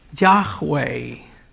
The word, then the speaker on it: Yahweh: Ya la pronunciación correcta es Yahweh.